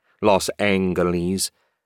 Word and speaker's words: Los Angeles: But for Los Angeles, he always pronounced it with a hard G and long E, Los Angeles.